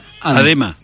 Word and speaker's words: Adema: Adema, por eso te le puse el acento, Adema. Fíjate que tiene acento en la E.